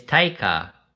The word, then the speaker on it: Taika: Yeah, but you're going to say, why is he going to say his name like that? It's Taika. – Dude, I don't know his name. Okay, whatever. What's his name? – Y-T-T.